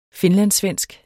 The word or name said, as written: finlandssvensk